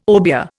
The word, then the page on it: obeah: Indian